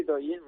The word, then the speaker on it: Douyin: Oye, decía que tienes millones de seguidores en esta red social, Douyin. ¿Lo he dicho bien? ¿Se pronuncia así? – Sí, sí, Douyin, muy bien.